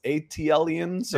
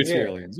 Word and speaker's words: ATLians: But, you know, at the end of the day, we're all, you know, how do you say it? Atlians, ATLians, or there you go. ATLians. – ATLians.